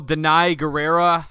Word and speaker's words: Danai Gurira: Danai Gurira, I think, is actually how you pronounce her name.